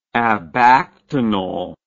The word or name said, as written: abactinal